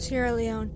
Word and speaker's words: Sierra Leone: Sierra Leone.